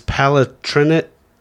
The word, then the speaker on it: Palatinate: So when people immigrated from, I can't pronounce this, Palatinate to Pennsylvania. – Perfect.